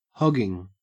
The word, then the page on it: hogging: Australian English